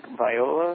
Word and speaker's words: Viola: I was listening to some of Frank Viola, is that how you say his name? – Yeah.